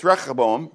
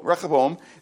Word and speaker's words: Rehoboam: And it's Rehoboam, because that H is really in the Hebrew, a cheth, so it's a chach, chach. So every time you say his name, you can clean your throat out, okay? It's Rehoboam.